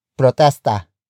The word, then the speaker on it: protesta: And they will go at protesta. Protesta. Protesta. Protesta is spelled as P-R-O-T-E-S-T-A.